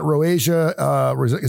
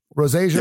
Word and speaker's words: rosacea: Rosacea. Is it rosacea? Is that how you say it? – Yep. – Rosacea.